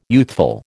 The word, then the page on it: youthful: American English